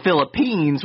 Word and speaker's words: Philippines: But they went to the Philistines and Am I saying that right? I feel like I'm Philippines. – Philippines, yeah. I was like, I'm not saying that right.